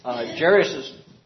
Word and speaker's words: Jairus: Jairus's, say the name. – Jairus. – Okay. We'll get it right yet. And Janie has another. – In my Bible, it's long A.